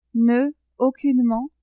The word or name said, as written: ne-aucunement